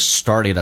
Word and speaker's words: started: 这边听力的难点叫 started，很多人听的不是很明白 What is started to sneeze and cough? – Started 这个单词美语当中中间的 T 要浊化成 D，那么它的标准的发音叫 started，